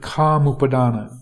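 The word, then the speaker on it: kāmupādāna: So that's a definition of kama upadana. It's K long A M U P long A D A long A N A.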